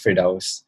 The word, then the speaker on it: Firdaus: Like, you know, my name is Firdaus.